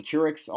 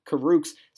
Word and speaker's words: Kurucs: I think I was saying Kurucs all offseason, and that's my fault. That's pure laziness. I did not put in the time to learn his last name. It is, in fact, pronounced Kurucs, so my apologies to him.